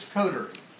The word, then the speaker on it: coterie: Well, the pronouncer that I listen to says it's coterie.